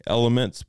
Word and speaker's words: LMNT: It's called LMNT, Elements, but it's spelled LMNT.